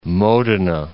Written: Modena

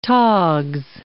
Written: togs

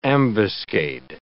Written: ambuscade

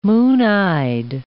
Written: moon-eyed

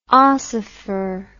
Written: ossifer

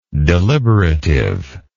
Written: deliberativeness

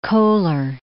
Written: Kohler

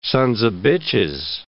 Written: sons of bitches

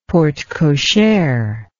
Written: porte cochere

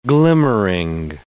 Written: glimmering

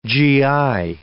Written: gi

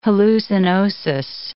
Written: hallucinosis

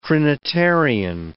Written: trinitarian

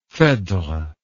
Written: phedre

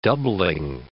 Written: doubling

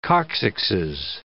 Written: coccyxes

